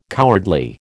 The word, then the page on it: cowardly: American English